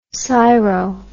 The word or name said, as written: syro